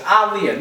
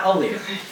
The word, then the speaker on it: Aaliyah: It's Aaliyah, not Aaliyah.